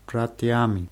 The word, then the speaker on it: братьями: Мой друг завтра идет на футбол с моими братьями. – Братьями. Die Betonung ist auf A.